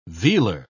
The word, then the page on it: vealer: British English